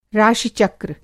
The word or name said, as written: राशि-चक्र